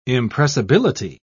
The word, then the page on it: impressibility: British English